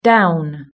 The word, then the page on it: down: British English